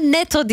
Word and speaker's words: Netto: Netto demiştim. Ama Netto diye okunur dedi bana. Ben buna çalışmıştım ya. Ne var Netto desem daha tatlı bence. Nettoşko desem hatta. Netto market deyince garip oluyor bir. Netto, NATO gibi bir şey oluyor.